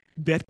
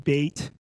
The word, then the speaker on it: Bet: This corresponds to the Hebrew Bet Knesset, or you'll hear a lot of the Ashkenazis pronounce it Beit. You'll hear me say Bet. You hear me say Kohen. You probably heard Rabbi Bernstein last week saying Kohen. So just a different pronunciation of the vowels.